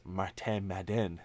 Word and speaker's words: Martin Madden: And like you pointed for Montreal, the fact that he speaks French, and Pierre LeBrun made a point to point out that it's pronounced Martin Madden on Insider Trading.